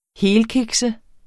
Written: helkikse

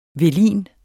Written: velin

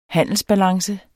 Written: handelsbalance

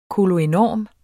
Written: koloenorm

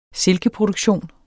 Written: silkeproduktion

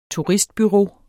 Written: turistbureau

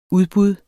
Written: udbud

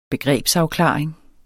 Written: begrebsafklaring

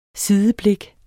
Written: sideblik

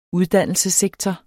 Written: uddannelsessektor

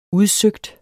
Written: udsøgt

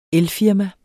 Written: elfirma

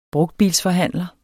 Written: brugtbilsforhandler

